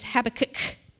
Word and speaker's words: Habakkuk: So it's H-A-B-A-K-K-U-K. Don't know how you say it, but the proper way is Habakkuk, okay?